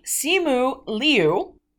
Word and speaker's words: Simu Liu: And he basically gave out a pronunciation guide for his name, which is Simu Liao. Simu Liu.